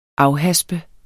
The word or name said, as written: AFHASPES